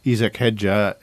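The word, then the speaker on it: Hadjar: Liam's current teammate, Isaac, I'll say the name properly, even no one else is, Isaac Hadja is taking the cursed seat as Max Verstappen's partner of the Red Bull team next year.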